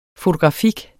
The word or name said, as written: fotografik